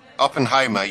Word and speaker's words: Oppenheimer: You don't pronounce the H, you don't? – Oppenheimer, you do.